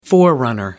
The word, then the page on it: forerunner: American English